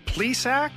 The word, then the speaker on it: Plesac: Zach Plesac? – Yes.